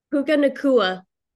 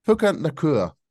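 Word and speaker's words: Puka Nacua: We've got Puka Nakua. How'd you pronounce his second name? – Puka Nakua. – Puka Nakua. All right, there we go.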